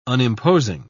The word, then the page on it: unimposing: British English